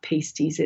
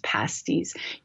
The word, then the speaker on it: pasties: It's not pastries. It's not pasties. It's pasties, Upper food of the gods.